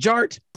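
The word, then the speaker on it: DJ Artt: And my name is Jared, aka DJ Art, with two T's for a double dose of that tink tink. The D is silent, so it's just Jart.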